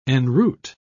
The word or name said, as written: enroot